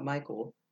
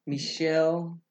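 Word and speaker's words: Michel: How do you say it? I've come to find out it's not Michael. – Michelle Piera.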